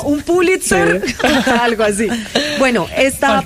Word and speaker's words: Pulitzer: Pronuncias muy bien Pulitzer. – Pulitzer.